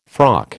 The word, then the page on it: frock: American English